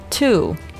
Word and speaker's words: two: 第一个单词，数字二 two，不要读成好比中文里呕吐的吐吐。注意它的这个元音是一个 u 的音，不是中文的乌鸦的呜的音啊，需要注意 two。